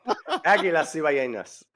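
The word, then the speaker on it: Águilas Cibaeñas: Aguias Cibaeñas. – You got me saying it wrong. Aguilas Cibaeñas.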